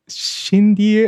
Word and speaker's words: Xindy: So Shindy, it's spelled X-I-N-D-Y, but I think it's like Shindy.